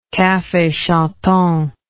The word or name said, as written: cafe chantant